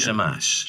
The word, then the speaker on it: Shamash: Shamash.